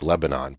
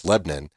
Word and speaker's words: Lebanon: A little town in the state of Ohio called Lebanon, and it's spelled like Lebanon, but it's Lebanon.